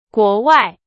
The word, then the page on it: 国外: Chinese